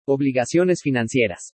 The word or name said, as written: OBLIGACIONES FINANCIERAS